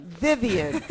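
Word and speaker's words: Vyvyan: Vivian.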